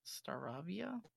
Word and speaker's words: Staravia: Staravia? – Yep.